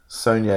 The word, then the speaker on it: Saulnier: Saulnier is how he pronounced it himself.